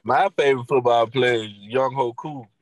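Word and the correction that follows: Younghoe: That is not how you pronounce his name. – I feel like that is how you pronounce it, though. – It's Young Way.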